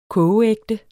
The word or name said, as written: kogeægte